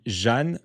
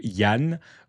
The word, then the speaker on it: Jan: Je ne sais pas si on dit Jeanne ou Yann. Ou Jan, ou je ne sais pas comment on le prononce. – On va dire Jeanne. – Jeanne, on va dire. – J-A-N.